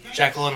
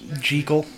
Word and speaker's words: Jekyll: You know, Jekyll and Hyde? Mm-hmm. It's not Jekyll. – Jekyll? – It is pronounced Jekyll.